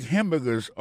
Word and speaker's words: hamburger: N'a pas manqué que dans mot hamburger, ça, comme t'as dit, il y a trois syllabes là-dedans, côté appuyé, puis plus ça, c'est sous premier syllabe là.